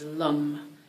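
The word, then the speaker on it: lam: So I'm going to give you the sound for the root chakra is lum. It's L-A-M, but it's not pronounced lamb. It's not lamb. It's more, it's the A and the O, like lum, lum. It's like an A mushed with an O, okay?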